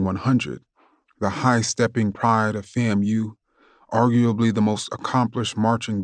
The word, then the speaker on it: FAMU: We called our school FAMU, pronounced as in family, FAMU.